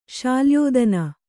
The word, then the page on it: ಶಾಲ್ಯೋದನ: Kannada